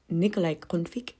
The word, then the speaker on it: Nikolaj Grundtvig: Mm, wtedy żył sobie taki ziomek jak Nikolaj Grundtvig. Czytaj Nikolaj Grundtvig.